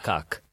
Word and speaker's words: kak: Maybe I actually understood it as cock as in the P. – No, no, no, no, no. It's spelled K A K.